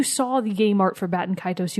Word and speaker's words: Baten Kaitos: It's B-A-T-E-N and then K-A-I-T-O-S. Baten Kaitos.